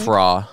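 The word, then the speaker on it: Frau: Husband is mon and wife is fura, I think. – Fra. – Fra. – Yeah, fra.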